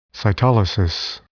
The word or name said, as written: cytolysis